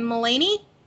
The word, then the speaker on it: Mulaney: He and I always say his name wrong, but I love him so much. John Mulaney? – Yeah. Yeah. You said it right.